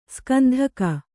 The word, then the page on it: ಸುಗಂಧಿಕ: sugandhila